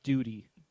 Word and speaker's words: Doughty: And I had a conversation online about how he and Doherty from L.A. – I believe it's pronounced Duty.